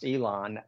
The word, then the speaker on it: Yilan: But so Yilan County, pronounced Elon, that has a reasonable beach for landing.